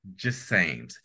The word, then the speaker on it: Jasames: Well, we'll be right back with more from Lynne Jasames.